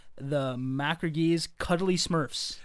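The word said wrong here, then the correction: Macragge: Yeah, it's McCraig.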